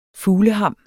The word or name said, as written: fugleham